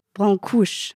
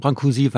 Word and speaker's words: Brancusi: findet man auch die Aussprache Brancusi. Wieso sagen Sie Brancusi? – Also wir sagen Brancusi, weil die Franzosen Brancusi sagen.